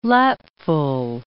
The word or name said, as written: lapful